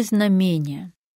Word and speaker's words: знамение: Даем правильный ответ. Знамение. Ударение зафиксировано нормой и восходит к словам «знак», «знамя».